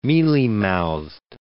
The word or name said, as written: mealy-mouthed